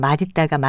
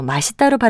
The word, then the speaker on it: 맛있다: 참고로 맛있다의 경우는 원칙적으로는 뜻있다처럼 맛있다가 맞는 발음이지만 우리 일상 언어에서 맛있다로 발음하는 경우가 훨씬 더 많기 때문에 맛있다도 표준 발음으로 인정했습니다.